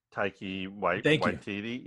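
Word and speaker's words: Taika Waititi: Taiki Waititi.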